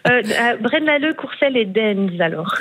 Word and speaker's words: Deinze: Et il y en a un, je m'excuse pour la prononciation, c'est Dinze. Ça s'écrit D-E-I-N-Z-E. – Ah, Dinze. – Dinze, pardon.